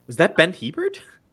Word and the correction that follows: Hebert: Yes. It is. Well, it's Ben. No. A bear. – A bear. – A bear. Sorry. My mistake. – I never know how to say his last name.